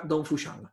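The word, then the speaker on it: Dong Fuxiang: Pero se pronuncia Dong Fuxian.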